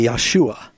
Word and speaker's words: Yeshua: If you were going to say Jesus' name in Hebrew, it would be Yeshua.